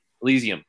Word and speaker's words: Elysium: One is scheduled for March, Disco Elysium, I think you pronounce it. Elysium, thank you.